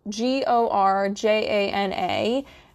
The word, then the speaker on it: Gorjana: It is spelled G O R J A N A, and the J is pronounced like a Y, so Gorjana.